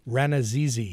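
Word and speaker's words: Rannazzisi: Steve, how do you pronounce his name? – Ranazizi.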